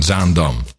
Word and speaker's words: Zaandam: Zaandam.